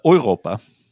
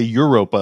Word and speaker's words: Europa: which also was weird because that's not how you pronounce that in German. – How would you pronounce it in German? – Europa. – Okay. – Yeah. But they say Europa, like specifically like the English pronunciation.